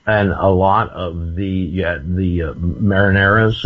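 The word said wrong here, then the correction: Marranos: is that how you pronounce it? – Murano.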